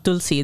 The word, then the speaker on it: Tulsi: Tulsi, that's how we would pronounce it.